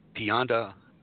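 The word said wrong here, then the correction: piñata: Pinata. How do you say that word? – Okay. The word you're trying to say is pinata. Say it from pin.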